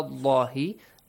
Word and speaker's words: Allah: Again, the same as ala allahi. The letter lam in the word Allah will be pronounced heavily.